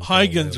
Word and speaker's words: Huygens: Huygens.